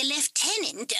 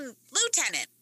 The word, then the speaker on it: lieutenant: I am Frank Fry, the lieutenant of Shame. – You mean lieutenant? – Nah, it's lieutenant. – That's not actually a word. Lieutenant. – It's the British pronunciation. Lieutenant.